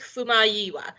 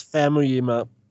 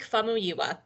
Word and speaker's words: Famuyiwa: It aired on November 15th, 2019, and it was written by Jon Favreau and directed by Rick Famuyiwa. Nah, that's not right. Let me do that one again. Famuyiwa. It's Famuyiwa, right? Famuyiwa. – That's the only way I can think of to pronounce it.